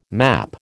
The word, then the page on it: map: American English